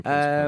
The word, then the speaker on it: Principe: Uh, my parents are from uh, Sao Tome, and uh, I think it's Principe, isn't it? You pronounce that? Yeah, Principe? Sao Tome. – Principe, um, yeah.